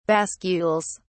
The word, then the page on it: bascules: British English